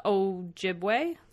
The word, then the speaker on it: Ojibwe: However, there is historical reports that this location was a scene of like a tribal warfare between the Mohawks and the Ojibwe tribe.